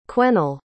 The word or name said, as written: quennell